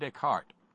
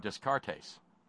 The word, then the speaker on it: Descartes: And for some reason or other, René Descartes came into the conversation. And I didn't introduce him. My buddy did and pronounced his name Descartes.